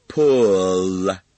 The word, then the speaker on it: Pull: 这是一个极其难发的单词，因为里面有一个短 u，还要加一个 l， l 以 l 结尾的单词都非常难读。它的发音技巧就是用舌尖舔住上齿龈。l l，请参看相关的章节，并听专门的那盒录音带，彻底攻克中国人的最大困难 L L 这个可怕的发音。我再读一下 Pull L Pull。